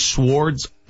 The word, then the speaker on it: sword: Swords. Well, how do you pronounce it? – Swords. I think the W is silent.